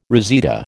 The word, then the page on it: reseda: American English